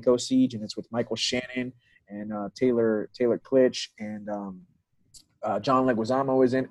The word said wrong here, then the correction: Kitsch: Did I pronounce his name wrong? I'm not sure. – Kitsch. Yeah. – Thank you very much. – Definitely not Taylor Klitsch.